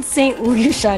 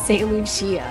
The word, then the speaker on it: St. Lucia: Wait, Ben, I think it's called St. Lucia, not St. Lucia.